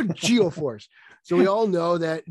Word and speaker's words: Geo-Force: It was G-Force, not Geo-Force. All right, Geo, that's how it's pronounced. It's Geo, G-E-O-Force.